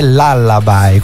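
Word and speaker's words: Lullaby: 1989 l'Allaby dei Cure, abbiamo trovato la pronuncia che è l'Allaby,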